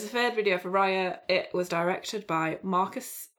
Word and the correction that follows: Marcos Siega: I think you, you pronounce it Marcos Siega.